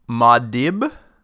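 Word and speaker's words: Muad'Dib: It's pronounced, the name is Maudib. Maudib? – Yeah. I think it's M-U-A-D apostrophe D-I-B.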